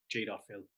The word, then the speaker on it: Jadotville: Um, there is a famous battle in the Congo um, during the 1960s, the Battle of, uh, Battle of Jadotville or Jadoville, uh, as the locals would pronounce it.